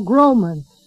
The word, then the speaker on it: Groman: San Benito, Texas, capital S-A-N, capital B-E-N-I-T-O, Texas, zip code 78586. My name is Paul Groman. That's spelled simply G-R-O-M-A-N, G-R-O-M-A-N.